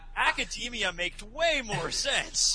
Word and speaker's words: Academia: I feel like an idiot now because you pronounce it Academia, and that makes way more sense than the way I pronounce it, which is Academia.